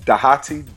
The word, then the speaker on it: Dhati: I am at Dhati, D-H-A-T-I. That's one of the benefits of having a name like Dhati.